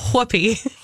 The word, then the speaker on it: Whoopi: Do you pronounce the H? – I believe you do. Whoopi? It's Whoopi.